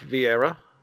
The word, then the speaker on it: Viera: We are here with Ralph Viera.